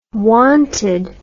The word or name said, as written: wanted